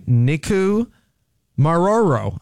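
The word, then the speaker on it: Nikumaroro: A 17-member team from Purdue University and the Archaeological Legacy Institute, they call it ALI, has launched a high-stakes expedition to Nicu Maroro. Let's see. I owe it to you to get this right. Nicu Maroro. I said it right.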